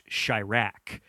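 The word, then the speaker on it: Chi-Raq: I believe that the title is meant to be pronounced Chirac, and it's about Chicago and how it's like kind of.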